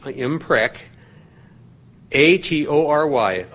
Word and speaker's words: imprecatory: I'll spell it. I-M-P-R-E-C, imprec, A-T-O-R-Y, atory, imprecatory.